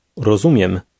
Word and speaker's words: rozumiem: Rozumiem. Miem. Miem. Zumiem. Zumiem. Ro. Rozumiem. – Did you hear the M sound at the end of that?